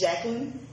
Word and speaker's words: Jacklyn: It's spelled like Jacqueline Kennedy Onassis, but on my driver's license, which came from my birth certificate, it's spelled J-A-C-K-L-Y-N, and it's Jacqueline.